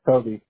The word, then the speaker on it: Toby: Toby Lowe. – How do you spell that? – T-O-B-Y-L-O-W-E. – G-O-B-Y, you said? Go be low. Okay, perfect. – No, Toby.